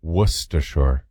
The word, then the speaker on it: Worcestershire: Like W-U-H-S-T-U-H-S-H-R. – Same as like, no kidding, Worcester. – Right, exactly. Yeah, Worcester is the county that I'm from in Massachusetts. – Worcestershire. – Worcester, Worcester. S-T-U-H, Worcestershire, S-H-R.